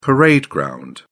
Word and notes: parade ground: British English

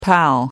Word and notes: pal: American English

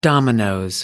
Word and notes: dominoes: British English

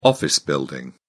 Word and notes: office building: British English

